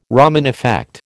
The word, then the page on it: Raman effect: American English